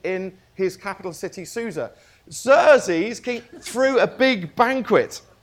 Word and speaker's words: Xerxes: Xerxes. It should be a Z. It's an X.